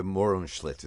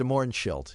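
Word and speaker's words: Mohrenschildt: George de Morenschlit is how you say it. – George de Morenschlit, yes.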